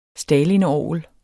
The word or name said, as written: stalinorgel